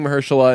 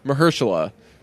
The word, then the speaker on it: Mahershala: Mahershala in the open, and then when everybody came in, he was like, "All right, on three, one, two, three, Mahershala."